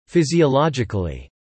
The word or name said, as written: physiologically